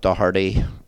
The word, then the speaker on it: Doherty: Or so I'm on Instagram, Mark Doherty. I just had to say that properly because it would be Doherty.